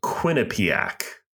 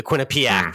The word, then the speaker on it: Quinnipiac: It's pronounced Quinnipiac. Just the way it's spelled.